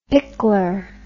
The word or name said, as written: pickler